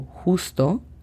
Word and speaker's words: just: Just, de solo o justo, sería G-E-S-T. Cuidado con la pronunciación de la G. Just. Estamos utilizando la pronunciación de la G que en español utilizamos como ya, ye, ye, yo, yo. Just. Fine sería F-A-I-N.